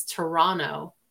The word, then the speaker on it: Toronto: You're from Toronto. – And as a native Torontonian, it's Toronto. That second T is silent.